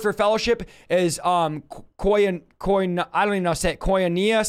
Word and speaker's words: koinonia: Koinonia. That's how you say it. Okay. Koinonia. Thank you.